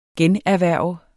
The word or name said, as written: generhverve